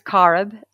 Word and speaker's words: Corrib: It's Carib Theater. Carib, yeah, it's an Irish word. And it's actually the river and the lake that Galway, the city of Galway, is on.